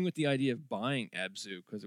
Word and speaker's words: Abzu: Abzu? Am I just pronouncing that right? – Yeah.